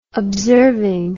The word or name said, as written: observing